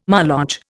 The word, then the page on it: Moloch: Indian